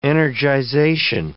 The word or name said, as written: energization